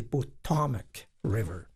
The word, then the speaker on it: Potomac: Le Potomac, que j'ai appris lorsque j'ai visité Washington, et je disais que le Potomac River, et c'est Potomac River. – L'accent sur la deuxième syllabe.